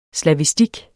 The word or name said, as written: slavistik